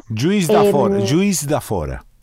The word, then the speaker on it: Juiz de Fora: pero no sé si es Juiz de Fora. – Juiz de Fora.